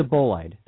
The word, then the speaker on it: bolide: It's a bolide. That's how it's pronounced.